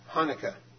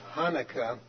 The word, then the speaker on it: Hanukkah: There's an Israeli news publication called Haaretz, which says that proper pronunciation of Hanukkah begins with a guttural sound, which, quote, often is described as the kind you might make if you had a fishbone stuck in the back of your throat.